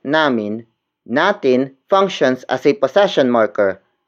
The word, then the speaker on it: natin: Word for word, it's nagising ang pusa natin. Nagising ang pusa natin.